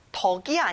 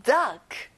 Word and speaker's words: duck: 덕이 아니라 duck. 잘했어요.